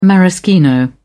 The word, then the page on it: maraschino: British English